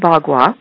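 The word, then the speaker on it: Bagua: I pronounce it Baghua, and Baghua. The meaning of it is 8-sided. Ba is 8, and gua is sides. Now, there's so many different ways of pronouncing it, so I just wanted to let you know that that's how I will be pronouncing it.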